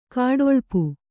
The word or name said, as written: ಗೂಢಲಿಪಿ